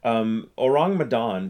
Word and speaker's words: Ourang Medan: Orang Medan, it's two words. It's spelled O-U-R-A-N-G-M-E-D-A-N.